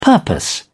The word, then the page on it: purpose: British English